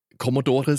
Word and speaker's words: Commodore: Jetzt du als jemand, der, wir haben es vorhin gesagt, du hast auch schon mal ein Buch geschrieben und ich habe frevelhafterweise den Namen Englisch ausgesprochen. Commodore sagt man ja eigentlich.